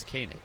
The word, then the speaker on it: Koenig: Do you think it's Koenig or Koenig? – According to the Baseball Reference pronunciation, it's Koenig. – Okay. Thank you. Spelled K-O-E-N-I-G.